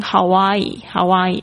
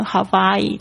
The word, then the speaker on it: Hawaii: In Hawaii，它有两种念法，当地人还会念成 Hawaii，就是 W 的声音会念成 V 的声音，Hawaii 或 Hawaii 都可以。